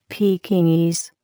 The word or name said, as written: Pekingese